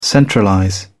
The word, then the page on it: centralize: British English